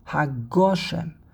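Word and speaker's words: HaGoshem (גשם): It's a long list of poskim that held very strongly the correct pronunciation is Mashiv HaRuach u'Morid HaGoshem. And the proof is the fact that you can say this statement independently.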